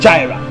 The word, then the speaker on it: Jireh: Jireh is spelled J-I-R-E-H, but it's pronounced Ra, Jireh, Jehovah Jireh.